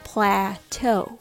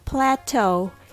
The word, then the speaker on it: plateau: The word is pronounced as plateau. Let's break it down. Pla, like plastic. Say it with smiley face. Eh. And toe sounds like the toe on your foot. The word plateau comes from French, and it retains the French pronunciation style. Practice saying it slowly. Plateau. Emphasize the second syllable, toe, to get the stress right. This funny spelling, E-A-U, at the end is from the French origin. That's why it has an elegant sound to it.